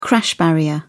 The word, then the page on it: crash barrier: British English